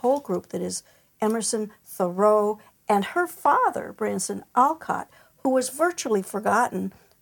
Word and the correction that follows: Bronson: Was it Bronson, I didn't say it correctly, sorry, Alcott, who pulled you to this?